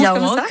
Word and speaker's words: Yaouank: C'est ça, se prononce Yaouank, comme ça.